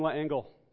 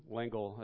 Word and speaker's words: L'Engle: Madeline Langle, or Madeline L'Engle, as I hear is rightly pronounced.